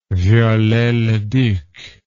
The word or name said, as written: Viollet-le-Duc